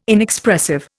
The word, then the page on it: Inexpressive: Indian